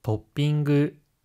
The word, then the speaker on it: トッピング: はい, トッピング라고 할 때 중간에 조금이 들어가고 ing 발음이라서 끝에 응그라고 해야 합니다.